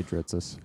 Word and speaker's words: Dritsas: like my whole family's like, just say Dritzis. Close enough. Yeah, exactly. It's like like uh, we, we usually get Dristus, Dristas, like everything but the name.